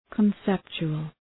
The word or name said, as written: conceptual